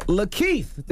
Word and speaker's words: Lakeith: Goes by the name of Lakeith.